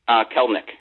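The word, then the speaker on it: Kelenic: Kelnick.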